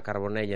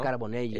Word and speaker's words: Carbonell: Me han recordado los mejores momentos de Gemma Mengual y Ona Carbonell en el dúo de la sincronizada española. – Qué bien pronuncias el catalán, ¿eh? – Bueno, claro. – Gemma Mengual.